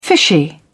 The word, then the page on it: fishy: British English